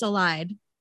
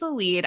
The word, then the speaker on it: Elide: My brain always says Elide. – Yeah, no, it's a lead.